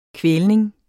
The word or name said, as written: kvælning